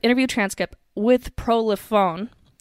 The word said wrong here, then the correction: Polifrone: or I just did I just butcher his name? Yeah, Polifrone.